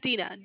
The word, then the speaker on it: Dina: It's Dina. D as in dog, I, N as in Nancy, A.